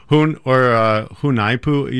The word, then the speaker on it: Junajpu: Hunaipu. You spell it J-U-N-A-J-P-U. – J-U-N-A-J-P-U.